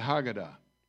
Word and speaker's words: Haggadah: I think this pronunciation is correct, called the Haggadah.